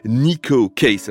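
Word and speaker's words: Neko Case: Je vous propose maintenant d'écouter la voix d'une chanteuse qu'on connaît assez peu, même très peu en France, c'est celle de l'américaine Nico Case. Alors Nico, ça s'écrit N-E-K-O, et Case comme une case.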